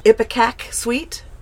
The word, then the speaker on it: Ipecac: Tell me about the song Ipecac Suite. – Yeah. – Did I pronounce that right? – Yes.